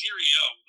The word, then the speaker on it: Syrio: I think it's Cereo, like S-Y-R-I-O.